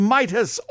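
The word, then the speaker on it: Mitas: It's the Harold Midas Orchestra. That's M-I-T-A-S, but you pronounce it like King Midas.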